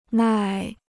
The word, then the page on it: 耐: nài